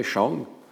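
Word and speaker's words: shang (上): Et il y a un morphème, alors je vais essayer de le prononcer, shang, et avec l'accent descendant,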